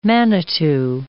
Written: manitu

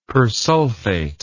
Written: persulfate

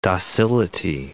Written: docility

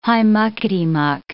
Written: high-muckety-muck

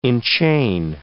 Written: enchain

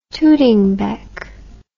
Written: tooting bec

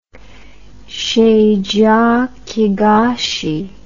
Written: chediak higashi syndrome

